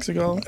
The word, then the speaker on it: Exegol: But this one is happens to be Kylo lands on Exegol, I guess you would say it. – Yep. – Exegol.